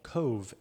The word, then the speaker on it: Cobh: But there's a little town really close to Cork City called Cove, and it's spelled COBH, but the BH says a V in Irish.